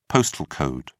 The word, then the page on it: postal code: British English